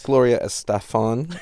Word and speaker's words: Gloria Estefan: Gloria Estefan, I believe. The Miami Sound Machine is pretty sure that's a proper pronunciation. – Yes, I believe it is.